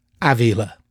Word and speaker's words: Ávila: The month of June, the month of Father's Day, this is the perfect time to talk about my dad, Edwino Bulcão Ávila. Ávila, as we pronounce in Portuguese.